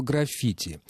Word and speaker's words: граффити: По поводу слова «граффити». Совершенно верно, Евгений, вы замечаете, что в этом слове ударение должно ставиться на второй слог.